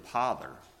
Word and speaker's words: pother: It said it is the vulgar pronunciation of the word pother, with a P instead of P as in Paul, instead of a B as in boy.